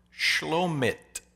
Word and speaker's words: Shlomit: Herodias, Herod's wife, had a daughter named Salome, which, by the way, you'll love this, her name in Hebrew is pronounced Shlomit, and it is derived from the root word shalom, meaning peace.